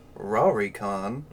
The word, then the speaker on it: Lolicon: So, Lolicon, and this is how it's pronounced on the Wikipedia page, so I'm not being racist, Rarikon.